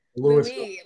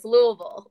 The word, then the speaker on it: Louisville: they say it correctly, without the Louis. Like you don't say Louis, it's Louisville, like straight up.